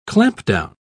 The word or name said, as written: clampdown